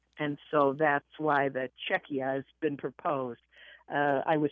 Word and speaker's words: Czechia: To say it again, it's Czechia. – Czechia. – Czechia. – Yeah. The I is always pronounced like an E, so it's Czechia.